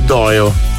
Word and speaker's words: Dolo: Quando vedete scritto il paese Dolo, non si legge Dolo. – Però dovremmo fare come in... – Si scrive Dolo, ma si legge Doio.